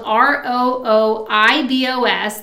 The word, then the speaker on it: rooibos: um, number six, is that rooibos tea, and that's spelled R O O I B O S. That's definitely the most interesting spelling for something pronounced rooibos.